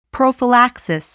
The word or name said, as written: prophylaxis